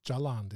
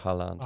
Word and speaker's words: Haaland: It's not Jaland, is it? – No, Haaland. – All right. Sorry, silent J.